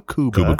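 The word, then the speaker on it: Kuba Kuba: It is a Cuba Cuba. – Cuba Cuba. That's it. – K-U-B-A.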